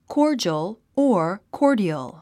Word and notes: cordial: kɔ́ːrdʒəl; コォジョォ

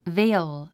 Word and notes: veil: véil; ベェイオゥ

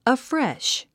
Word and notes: afresh: əfréʃ; アフレェッシュ

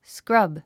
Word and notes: scrub: skrʌ́b; スクラブ

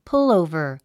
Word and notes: pullover: púlòuvər; プゥオーバー

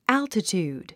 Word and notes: altitude: ǽltətjùːd; アルティチュード

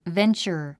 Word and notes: venture: véntʃə r; ベンチャァ

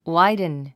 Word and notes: widen: wáidn; ワイドゥン

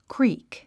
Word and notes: creek: kríːk; クリーク

